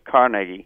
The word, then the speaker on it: Carnegie: the correct pronunciation is Carnegie, accent on the first syllable. But, of course, that's a hopeless task to get all Americans, especially New Yorkers, with Carnegie Hall, so the correct pronunciation, I'm sorry, is Carnegie. I've done it myself. Carnegie is the correct pronunciation.